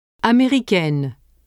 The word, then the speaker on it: Américaine: The addition of E to masculine forms ending with the letter N denasalizes the vowel un. It becomes E and the N is pronounced. – Américain. Américaine. Marocain. Marocaine. Mexicain. Mexicaine. – Note that when un is spelled I-E-N in the masculine form, an extra N is added in the feminine form.